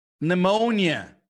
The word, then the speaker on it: pneumonia: Por exemplo, quando é P seguido de N, o P é mudo pneumonia, pneumatic.